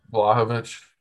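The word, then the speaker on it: Vlahović: Vlahovic.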